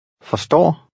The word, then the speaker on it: forstår: The RS combination usually makes the R lightly pronounced. Read 19. – Forstår.